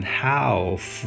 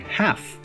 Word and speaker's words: half: 比如说，我们生活中非常爱用到的一个词，一半，一半一半，h a l f，它的发音规则就是跟 salmon 一样的，你不会把它读成 half，相反，我们直接说成 half。 half a l 在这里也发的是梅花音 a 的音，